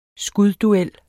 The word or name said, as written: skudduel